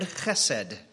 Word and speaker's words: chesed: My favorite word in the whole of the Bible is the Hebrew word chesed. You can spell it however you want, but if you want to get close, it would be H-E-S-E-D, and the H is hard, right? Chesed.